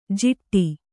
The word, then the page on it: ಜಿಟ್ಟಿ: jiṭṭi